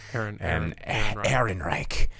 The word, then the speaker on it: Ehrenreich: Well, and they put so much emphasis on the two guys that aren't good comedians, which is O'Shea Jackson and Alden Ehrenreich, or Aldenreich, however you pronounce his last name. – Ehrenreich.